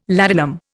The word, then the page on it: ladanum: Indian